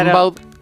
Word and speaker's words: Rimbaud: Claro, como se escribe, se pronuncia Gamboa, pero claro. Rimbaud.